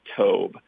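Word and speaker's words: tov (טוֹב): And in the Hebrew derivation, the word good is a word pronounced tov, and it's translated as good in our English Bibles.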